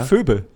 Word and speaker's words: Phoebe: Ist das Phoebe? Das Phoebe ausgesprochen? – Ja. Ja. – Echt? – Ja, Phoebe. Ja.